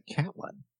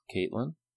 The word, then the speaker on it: Catelyn: Who pronounces it Catlin? – Someone who's not Caitlin. – That's the way it's pronounced, actually.